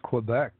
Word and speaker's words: Quebec: How do you say it in Canada? – It's Quebec.